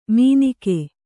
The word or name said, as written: ಮೀನಿಕೆ